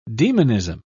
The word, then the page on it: demonism: British English